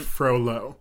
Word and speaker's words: Frollo: Frollo. – Yeah. – It's just Claude Frollo. – Yeah. – that's like the easiest Frenchman name in the world. – What did I say? – Claude Frollo or something like that.